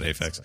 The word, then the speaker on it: Aphex: I don't know how you say it. Is it Aphex? Yeah, I've always said Aphex.